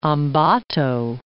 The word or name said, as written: Ambato